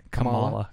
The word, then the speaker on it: Kamala: How do you say her name? – Kamala. – Okay. It's like koala.